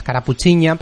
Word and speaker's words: Carapuchiña: Carapuchina con N, porque la auténtica es carapuchiña, pero las Ns viene, las vienen fatal.